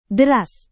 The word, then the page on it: deras: Indonesian